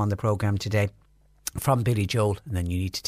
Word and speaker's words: Billy Joel: And another text in says, I always thought that the way to pronounce Billy Joel's name was Billy Joel. Yeah, I used to say Billy Joel as well, but we have been told that his name is Billy Joel. So I think we've been wrong when we've been saying Billy Joel. So it's Billy Joel is how you pronounce it.